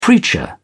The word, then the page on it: preacher: British English